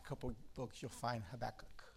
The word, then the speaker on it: Habakkuk: Is that how you pronounce it? – Habakkuk. – Habakkuk. Sorry. Habakkuk.